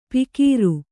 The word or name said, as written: ಪಿಕೀರು